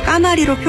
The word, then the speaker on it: 가마리: 발음이 까마리라고 해서 까마리로 표기하면 틀리는데요. 가마리가